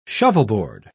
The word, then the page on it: shovelboard: British English